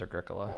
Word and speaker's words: Agricola: Well, I took 3 years of high school Latin, so I know that it's pronounced Agricola.